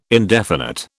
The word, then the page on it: indefinite: British English